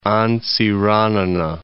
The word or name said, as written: Antsiranana